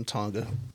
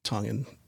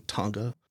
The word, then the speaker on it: Tonga: My mom was an immigrant from Tonga, and all my Tongan peeps out there are going to get mad at me for saying Tonga. It's Tonga. That's how you pronounce it.